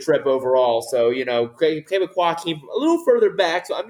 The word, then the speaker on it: Québécois: The 2 would be called Québécois.